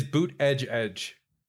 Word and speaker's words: Buttigieg: It is boot edge edge, in case you're curious.